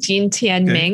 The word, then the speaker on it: Jin Tianming: So it would be Tianming, Jin Tianming. Okay. – And then it's kind of like, think of it as like J-E-E-N.